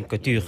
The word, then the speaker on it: Couture: Sí, Couture, sí.